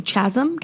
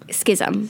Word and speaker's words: schism: And so there's obviously a clear, what's the right pronunciation? Chasm? Chasm? – Schism. – Schism. Okay, neither.